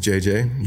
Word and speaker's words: Jay-J: So my name is JJ, Jeremiah James. – Yeah. So J-A-Y-J.